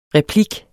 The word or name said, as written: replik